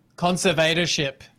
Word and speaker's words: conservatorship: We've been talking a lot about her convertership. I can't even say it. How do you say it? – Conservatorship. – Conservatorship. Thank you very much.